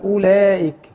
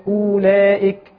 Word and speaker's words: اولئك: الالف والواو والياء اذا كان عليهم طبعا الدائره المستديره او الصفر المستدير ده فلا تلفظ. – تمام. – البعض يلفظها في نص الكلمه يقول اولئك او يمد فيها شويه يعني.